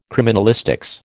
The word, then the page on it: criminalistics: American English